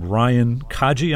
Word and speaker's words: Kaji: named Ryan Kaji. Am I saying that right? – That's right.